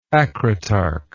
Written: acritarchous

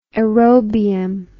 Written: aerobium

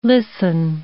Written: listen

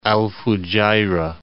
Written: Al Fujayrah

